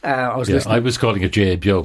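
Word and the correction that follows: GAAGO: but it's go.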